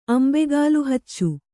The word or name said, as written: ಅಂಬೆಗಾಲುಹಚ್ಚು